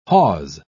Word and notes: hawse: British English

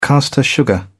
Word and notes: caster sugar: British English

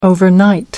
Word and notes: overnight: American English

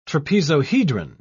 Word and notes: trapezohedron: British English

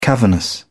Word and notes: cavernous: British English

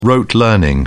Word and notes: rote: British English

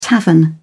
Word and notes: tavern: British English

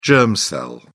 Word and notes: germ cell: British English

